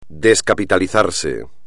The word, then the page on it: descapitalizarse: espagnol